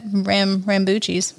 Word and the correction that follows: rambutan: Is that how you say it? No. Rambutan.